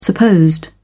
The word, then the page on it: supposed: British English